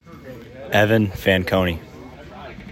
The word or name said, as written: Evan Fanconi